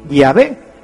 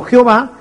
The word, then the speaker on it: Yahvé: Y por eso nosotros sabemos que la pronunciación correcta de Yahvé es así, Yahvé, y no Jehová,